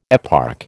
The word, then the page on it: eparch: American English